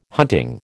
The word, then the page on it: hunting: American English